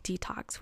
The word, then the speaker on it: DTOCS: and founder of an amazing company called Detox, which is spelled D-T-O-C-S.